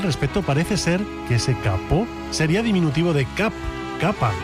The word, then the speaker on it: capot: El alemán tomó prestado ese kaputt en el siglo XVII del francés capot, hoy pronunciado capó concretamente,